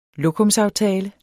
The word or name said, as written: lokumsaftale